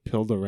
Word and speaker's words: Pildo: Pildo rhymes with dildo, and that's a running joke on the YouTube.